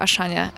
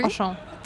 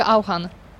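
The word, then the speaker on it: Auchan: E, po polsku mówi się, e, jak się mówi? Oszą. Tak. A właśnie Rosjanie, ja już teraz nie mogę mówić inaczej, mówią Aszan i pisze się to Aszy, Anny. U nas się kiedyś mówiło Auhan, Więc, ale jest Oszą, no okej.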